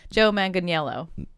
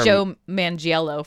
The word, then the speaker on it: Manganiello: Joe Manganiello? – No. Joe Manganiello. – Manganiello. Joe Manganiello. He is from... the beefcake. – I thought stripper. – It was Joe Mangiello.